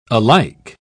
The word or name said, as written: alike